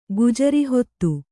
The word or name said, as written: ಗುಜರಿಹೊತ್ತು